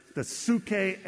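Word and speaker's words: psyche: In Greek, it's the psuche and the pneuma. So they both begin with P, a silent P.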